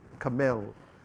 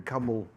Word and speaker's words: Carmel: And Mount, the word is Carmel, is how they pronounce it, Carmel, rather than camel. Otherwise, you'd be saying it for camel.